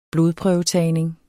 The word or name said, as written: blodprøvetagning